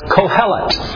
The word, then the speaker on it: Kohelet: The Hebrew title of the book can be pronounced in English as Kohelet.